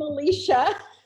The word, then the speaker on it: Alesia: I'm Alesia.